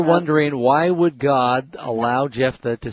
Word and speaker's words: Jephthah: Yeah, Jephthah, well, at least in English. – Okay. – How do they say it in South Africa, Pastor Ross? – Same thing, Jephthah, yeah.